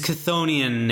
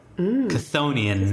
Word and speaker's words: Chthonian: His Chthonian nature, I think that's the first time I pronounced it correctly. Chthonian